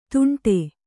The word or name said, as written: ತುಂಟೆ